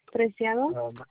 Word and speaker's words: Preciado: Preciado.